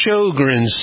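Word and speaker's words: Sjögren: and it's spelled not the way it sounds, S-J-O-G-R-E-N, if you want to read about it, S-J-O-G-R-E-N. It's pronounced Sjogren's syndrome,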